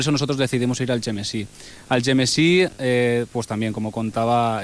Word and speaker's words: Algemesí: Pues bueno, nosotros al pueblo que fuimos es Algemesí, como se pronuncia en valenciano.